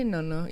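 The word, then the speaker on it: nonno: If you're going to say Nono, you have to say it right. So it's not no, it's no.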